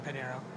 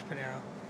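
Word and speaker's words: Pinheiro: It's the first four letters of my name, so Serge, S-E-R-G, and then my whole last name, Panero. For both Instagram and Twitter, Serge Panero. – Can you spell that for everyone? – So S-E-R-G-P-I-N-H-E-I-R-O. Just the same.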